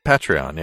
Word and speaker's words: Patreon: That pronunciation actually drives people crazy for some Patreon? – Reason. Patreon? Patreon, yeah. – Is it? – Opposed to Patreon.